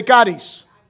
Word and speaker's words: charis: It is the word charis. Say charis. It is spelled C-H-A-R-I-S.